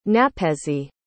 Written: napezi

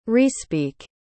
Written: respeak

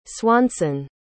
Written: Swanson

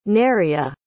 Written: Neriah

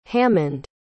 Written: hammond

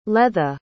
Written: leatha

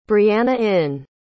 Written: breanainn

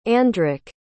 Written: Andric

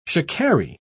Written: shikari

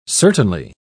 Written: certainly

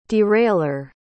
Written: derailer

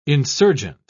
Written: insurgent

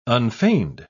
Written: unfeigned

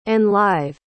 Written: enlive

